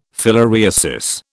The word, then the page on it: filariasis: British English